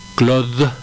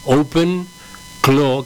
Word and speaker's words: OpenClaw: A ver, aclaremos antes de seguir, porque el nombre capaz que puede confundir, no es Claude, sino Claw. Open Claw, que se escribe C-L-A-W, ¿no? – Es correcto.